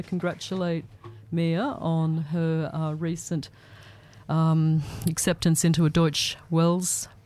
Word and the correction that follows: Deutsche Welle: program in, or Deutsche Welle, I think that's the right way to say it, isn't it, Mia? – Deutsche Welle. – Deutsche Welle, thank you.